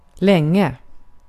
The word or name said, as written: länge